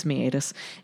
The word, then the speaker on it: meatus: Looks like meatus. Rhymes with wheatus, but does. – No, it's meatus.